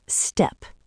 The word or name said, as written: steppe